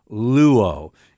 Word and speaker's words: luo: But, you know, your first verb that you learn in Greek 101, New Testament Greek, you start with the verb luo. In English, this would be L-U, long O.